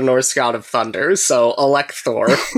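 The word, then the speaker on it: Électhor: You don't pronounce the letter H in French, so it'd be Electhor, which is not so impressive.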